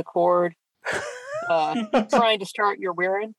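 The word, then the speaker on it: Wirrn: And by the way, if you're wondering at home why I keep going on about the pronunciation, it's because it is pronounced Wirin, but Martyr for some reason gives it an extra R, which is just strange.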